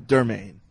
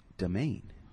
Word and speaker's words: Domaine: So I thought Dermaine. That's the first thing I thought of. – But it's Domaine.